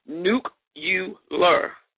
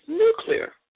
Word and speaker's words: nuclear: Okay, the incorrect pronunciation, listen to me, is nuke-u-ler. The correct pronunciation is nuclear. Just let it flow.